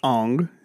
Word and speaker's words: Aang: So I was like, first of all, his name's not Ang, it's Ong.